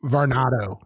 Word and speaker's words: Varnado: I know that there's rumors and rumblings of Sasha Banks, now known as Mercedes Vernada, right? Did I say that right? – Varnado.